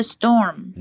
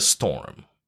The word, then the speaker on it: storm: OK, and the storm, you know, the M is lost, storm. So storm. A lot of people pronounce it like that, so remember the last syllable.